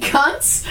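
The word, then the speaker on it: Kuntz: Oh, and it's pronounced cunts, too. – Cunts. – You know, it's not cunts or something.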